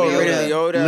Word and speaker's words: Liotta: Oh, Liotta.